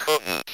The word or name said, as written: couldn't